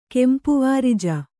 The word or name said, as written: ಕೆಂಪುವಾರಿಜ